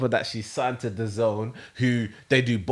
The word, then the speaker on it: DAZN: Is it DAZN? – Yeah, DAZN.